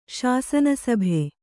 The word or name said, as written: ಶಾಸನಸಭೆ